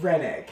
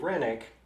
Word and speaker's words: Roenigk: R-O-E-N-I-G-K. – Oh, but good heavens. – Rennick. – Hearing Rennick, I'm like, oh, it's like the first time you learn how to say Llewellyn when you're comparing to how it's spelled.